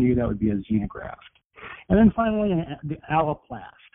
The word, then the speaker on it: xenograft: It's spelled with an X, xenograft, which is a graft from a different species.